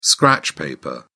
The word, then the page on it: scratch paper: British English